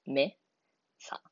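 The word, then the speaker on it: mesa: 例えば、さっき言ってたメサっていう単語だったら、最後が母音で終わるので、最後から 2番目の音節が単語に、アクセントを置く位置になります。ここだったら、メサ。目のところにアクセントを置きます。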